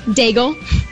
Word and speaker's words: Daigle: Daigle. – How do you spell that? Like bagel with a D. – D-A-I-G-L-E? – Yep.